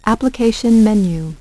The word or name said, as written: Application menu